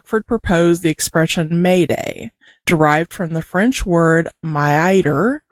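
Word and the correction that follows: m'aider: I believe, that means help me. – No, actually, it's pronounced Mayday. – Gotcha. Okay. – Surprisingly enough.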